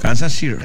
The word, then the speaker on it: Kansas City: No, usted, o sea, en español pronuncia City. – Y es Kansas City. City, o sea, la T suena como R. City.